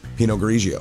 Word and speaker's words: Pinot Grigio: And when they got her to jail, a corrections officer discovered that she had a full bottle of Cupcake Vineyards Pinot Grigio. Did I say it right? I'm not a wine, women, and chocolate. – You're good. – Pinot Grigio.